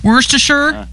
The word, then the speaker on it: Worcestershire: Worcestershire? – Yeah, very good, yeah.